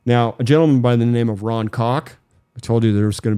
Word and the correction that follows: Kauk: It's pronounced Kauk. – Kauk? – Kauk. – This Ron is C-A-U-C-K? – K-A-U-K.